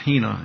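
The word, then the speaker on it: hina (ἵνα): It's pronounced hina, and it's translated into the English by the word that.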